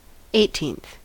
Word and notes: eighteenth: American English

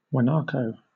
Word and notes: guanaco: British English

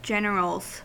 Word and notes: generals: American English

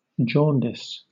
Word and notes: jaundice: British English

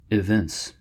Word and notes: evince: American English

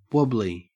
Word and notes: wobbly: Australian English

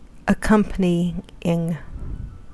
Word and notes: accompanying: American English

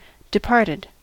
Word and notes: departed: American English

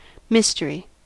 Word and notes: mystery: American English

